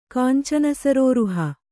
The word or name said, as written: ಕಾಂಚನಸರೋರುಹ